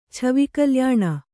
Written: ಛವಿಕಲ್ಯಾಣ

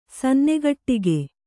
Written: ಸನ್ನೆಗಟ್ಟಿಗೆ

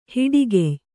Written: ಹೆಡಗಯ್

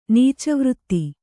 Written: ನೀಚವೃತ್ತಿ

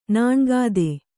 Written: ನಾಣ್ಗಾದೆ

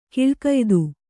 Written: ಗುಳಿಗೆಯೆತ್ತು

